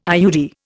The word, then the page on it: IUD: Indian